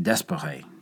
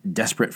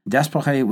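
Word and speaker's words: d'Espèrey: Guillaumat was transferred back home in June of 1918, and French General Louis Franchet Desperet took over. Desperet was nicknamed Desperate Frankie by his British subordinates. But this illustrated more the inability of the Brits to properly pronounce his name than it did his personality.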